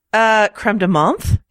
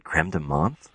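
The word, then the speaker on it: crème de menthe: A little bit of vanilla ice cream and some, is that how you pronounce it? Creme de menthe? – Or, that's the snooty way of saying it. You can say creme de menthe or whatever.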